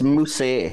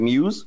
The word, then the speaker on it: Muse: and apparently it's pronounced Moose. It's like there's an accent on the E, but I was just reading it as Muse.